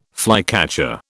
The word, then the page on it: flycatcher: British English